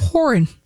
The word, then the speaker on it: Horan: Is that how you say his name? – Horan. – Is there a whore within? – Niall Horan. Okay.